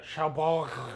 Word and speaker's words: Cherbourg: I know I'm Americanizing a French place, but... – Cherbourg. – Exactly. Pronounce it properly.